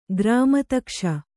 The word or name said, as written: ಗ್ರಾಮತಕ್ಷ